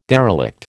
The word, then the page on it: derelict: American English